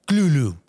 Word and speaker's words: Cthulhu: Ah, mira, según S.T. Joshi, académico y biógrafo de Lovecraft, se pronuncia Cthulhu, con la K bastante gutural.